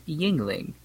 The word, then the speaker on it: Yuengling: It looks weird because it's Yuengling. It's pronounced Yuengling, and it's the anglicized version of Yuengling, which is, it has umlauts. When they came to America, they got the umlaut out.